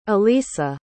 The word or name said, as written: elisa